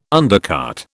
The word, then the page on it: undercart: British English